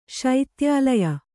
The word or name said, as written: ಶೈತ್ಯಾಲಯ